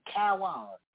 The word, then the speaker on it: Cowan: C-O-W-A-N. – C-O-W-A-N. Kawan.